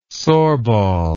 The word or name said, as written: sorbol